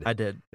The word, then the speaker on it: id Software: Did you say ID Software or ID? – I did. – It's ID. Yeah, Joey's correcting you in the chat room. It's ID Software. – Oh, thank you, Joey. ID Software releases Doom for PC.